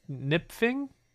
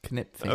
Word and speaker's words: Knipfing: It looked like a KR, but it's a KN, so I assume it's a silent K. Kevin George Nipfing. – Knipfing.